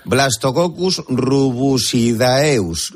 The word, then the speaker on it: Blastococcus rubusidaeus: Blastococcus rubusidaeus. Lo he dicho bien, ¿verdad? – Perfecto.